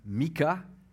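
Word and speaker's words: Michée: On a un texte de, l'Ancien euh, Testament ce matin, un, des petits prophètes, Miché ou Mika, comme il est prononcé en anglais, et c'est plus près de la prononciation hébreu aussi, qui signifie euh, qui est semblable à Dieu?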